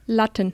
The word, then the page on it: bars: german